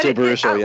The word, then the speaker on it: Jay Baruchel: And Jay Baruchel, am I saying that right? – But Jay Baruchel, yeah.